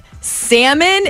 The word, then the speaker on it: salmon: From the 213, they said, today I learned that salmon is pronounced without the L.